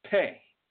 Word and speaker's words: Pe: I'm going to do one more, which is it's pronounced P-E, but it's pay.